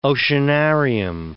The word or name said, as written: oceanarium